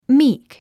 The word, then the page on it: meek: míːk; ミーク